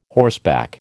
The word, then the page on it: horseback: American English